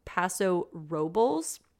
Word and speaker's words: Paso Robles: So first of all, I think it's important that our listeners know that it is pronounced Paso Robles.